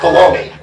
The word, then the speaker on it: Bologna: Cardinal Guidi, the Archbishop of Bologna, it's pronounced Bologna. I grew up pronouncing that Bologna.